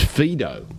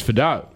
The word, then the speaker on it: Vidot: I was told initially it was Fido. – Okay. – But no, I think you're right. It's Fido. Tabuai-Vidot.